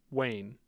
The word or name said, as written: Wayne